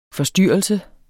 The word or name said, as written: FORSTYRRELSEN